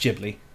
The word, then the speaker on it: Ghibli: it's pronounced Ghibli, not Ghibli. Because everybody outside of Japan just is like, oh, Ghibli. And that's just not how you pronounce it as a J whatsoever.